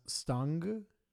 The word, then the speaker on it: Stange: And again, we're bad at this, but I think it's pronounced Stang. So STANGE, which means either spike or rod.